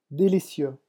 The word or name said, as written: delicieux